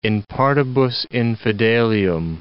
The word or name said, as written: in partibus infidelium